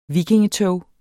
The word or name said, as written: vikingetog